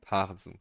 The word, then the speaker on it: Parensen: Wie alle Moderatoren in seiner gesamten Karriere falsch gesagt haben, Michael Parensen. – Parensen, genau.